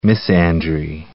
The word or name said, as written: misandry